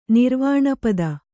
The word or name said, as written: ನಿರ್ವಾಣಪದ